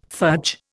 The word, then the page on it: fudge: Indian